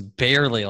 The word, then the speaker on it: Byerly: So it's actually even simpler. It's barely, like we barely, barely got it done.